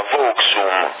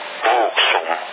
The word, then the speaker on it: Wouxun: A parte che c'è quelli, il fratellino più grande, che è buono, si chiama Voxum, si scrive WOUXUN. Voxum.